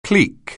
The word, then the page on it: clique: anglais